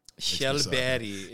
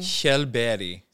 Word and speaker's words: Kjellberg: My last name, Kjellberg, is a Swedish. – KJ, baby. – KJ, you know it. Mm. Yep. Kjellberg is, I guess, the correct Swedish pronunciation.